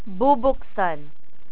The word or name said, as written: bubksan